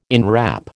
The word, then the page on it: inwrap: American English